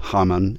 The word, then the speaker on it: Hamann: It's, my pronunciation anyways, is Haman, kind of like the villain from the Jewish story of Esther.